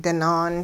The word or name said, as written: dinaan